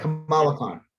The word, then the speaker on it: Kamala Khan: Kamala Khan.